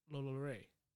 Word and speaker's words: Lalaurie: And we're going to talk about Madame Marie Delphine Lalaurie. I can never say the name right. – Lalaurie. – It's French. – It's French with an accent? – French with an accent.